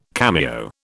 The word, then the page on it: cameo: British English